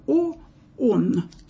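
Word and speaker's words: О, он (ὁ ὤν): Так вот, что это такое? О, он, так это читается, О, он.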